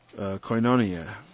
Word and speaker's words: koinonia: Koinonia, or koinonia, it doesn't really matter how you pronounce it.